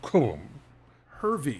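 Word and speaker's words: Cobham-Hervey: I'm sure the H is silent. It's like Cobham. – Cobham. Cobham. Cobham. – Hervey. – Cobham. Hervey. – Like Harvey, hyphen. But with an E.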